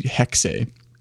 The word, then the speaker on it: Hexe: So it's actually, you know, a bit of a weird one, but it's actually Hexe is how you pronounce it.